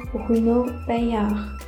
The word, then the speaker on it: Bruno Paillard: 今日教大家布鲁诺百样香槟的法语发音：Champagne Bruno Bayard，Champagne Bruno Bayard，Champagne Bruno Bayard。